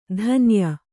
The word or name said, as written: ಧನಾಯೆ